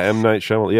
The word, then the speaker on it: Shyamalan: Did I say his name right? I have no clue. – Yeah, M. Night Shyamalan. Yeah, I'm a fan, actually.